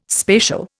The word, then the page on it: spatial: Indian